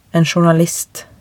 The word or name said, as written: en journalist